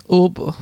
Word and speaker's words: Uber: Heißt da, das heißt Uber, da das ist anders ausgesprochen wie wird es ausgesprochen? – Ober.